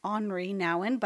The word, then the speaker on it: Henri Nouwen: This quote is from Henry Nouwen, who I heard you actually pronounce his name on Henri Nouwen, but I'm going to say Henry because that's what I'm used to.